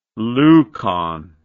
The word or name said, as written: leucon